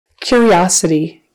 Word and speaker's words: curiosity: Curiosity. Spelled with a U-R, but we say it more like a K-Y-E-A-R, kind of a curiosity.